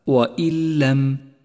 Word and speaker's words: وإن لم: والواجب هنا أن نسقط النون الساكنة من اللفظ تماما وأن نشدد حرف اللام الذي بعدها، ولذلك لونت النون باللون الرمادي إشارة إلى عدم نطقها، تلفظ هكذا وإن لم وإن لم.